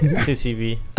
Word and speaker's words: 2CB: 2CB? How do you say it? – 2CB. – I was calling it CB2.